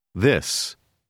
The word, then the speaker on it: this: Prononcez la dernière lettre comme s. – This.